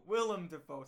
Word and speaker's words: Willem Dafoe: William Dafoe. Willem Dafoe. Say his name right.